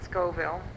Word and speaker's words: Scoville: Pat Scoville. – And spell it, please. – S-C-O-V-I-L-L-E.